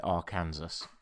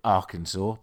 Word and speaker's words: Arkansas: I remember the first time I ever spoke about Arkansas, I called it Arkansas because that's how it's spelt phonetically. And I still don't quite understand how anyone ever managed to come up with Arkansas, but that's the place that it is.